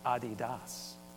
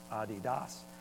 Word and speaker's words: Adidas: Oh, and by the way, it's not pronounced the way that most of you, it's Adidas, Dassler, Adidas.